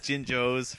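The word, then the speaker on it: Jinjo: The jingos. – I believe it's pronounced jinjos for starters.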